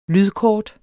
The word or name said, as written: lydkort